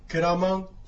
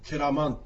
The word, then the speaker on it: ケラマン: でこのアイヌに対して、私は分かりますという答え、これはサルガー流域ですから、ケラマンというふうに発音いたします。これはもともとはクエラマンということがサルガー流域ではくっつきまして、ケラマンというふうに発音します。